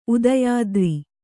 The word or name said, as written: ಉದಯಾದ್ರಿ